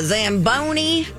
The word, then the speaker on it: Zamboni: Zamboni in the house. I do like that word a lot. – It's a fun Zamboni. Word. – Yeah, it rhymes with macaroni.